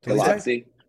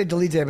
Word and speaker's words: Dolidze: And really quick, how do you pronounce his last name? – Dolidze. Yeah. – Say it again. – I say Dolidze, but I might be wrong. – Yeah, that sounds right.